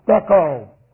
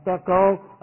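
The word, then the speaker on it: stecho (στήκω): The word is stecho. And stecho, if you're interested, looks like this. It is S-T long E, K long O. Stecho.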